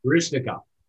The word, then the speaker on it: Baryshnikov: I forgot how do you say it? Barakshanov. – Baryshnikov. – Baryshnikov in White Nights.